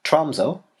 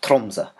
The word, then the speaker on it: Tromsø: So for most English speakers, the city I'm going to talk about is called Tromsø, but the Norwegians would call it something more along the lines of Tromsø, and that's how I'm going to try to call it, as close as possible to the actual pronunciation.